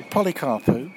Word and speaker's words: Polycarpou: Polycarpou.